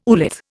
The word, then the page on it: oolith: Indian